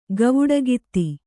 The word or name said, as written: ಗವುಡಗಿತ್ತಿ